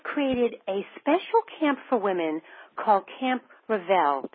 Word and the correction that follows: Reveille: It's Reveille. You know the song that.